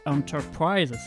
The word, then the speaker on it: Enterprise: Évidemment, c'était histoire de se replonger un petit peu dans le Spaceship Enterprise, c'est comme ça qu'on prononce. Le vaisseau de Star Trek, un très bel accent, vous avez vu ça.